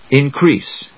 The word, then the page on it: increase: in・crease; ɪnkríːs